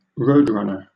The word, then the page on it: roadrunner: British English